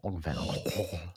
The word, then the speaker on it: Van Gogh: I don't know if you've ever learned about like the proper way to pronounce Van Gogh. It's like a G... Like a... – Van Gogh. – It's like a with a it's like a PF at the end.